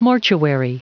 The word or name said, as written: mortuary